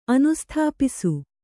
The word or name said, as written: ಅನುಸ್ಥಾಪಿಸು